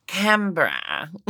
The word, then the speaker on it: Canberra: Okay, I think I've got a sense of, am I saying it right? Canberra. – Yeah. Well, they say the Berra. – Canberra.